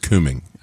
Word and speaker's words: Cumming: I want to go back up to Georgia, because you mentioned earlier the city of Cumming, Georgia, and it's pronounced Cumming.